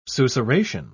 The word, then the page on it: susurration: British English